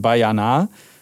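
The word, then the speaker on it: Baianá: Und der Song heißt Bayana mit einem Akzent auf dem letzten A, deswegen klang das jetzt so komisch.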